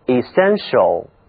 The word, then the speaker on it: essential: 本质的，这是 essential，就是给精华 essence 加了一个形容词后缀 til show，注意啊，这个读音就变了，是 essential。这里的这个字母 t 它的读音呢是 sh，它就像那个微积分符号，对，就是那个原因 essential。